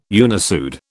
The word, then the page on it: unissued: British English